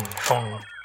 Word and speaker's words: Phaune: I mean, phone radio. It's spelled P-H-A-U-N-E, but it's pronounced phone.